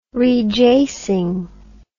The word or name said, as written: rejasing